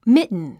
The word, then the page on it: mitten: mítn; ミトゥン